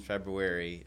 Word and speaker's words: February: So you think about all those social media posts in February, it didn't matter because nothing happened. – How do you say that month? – February. What? – It just sounds weird. – Well, there's an R in there.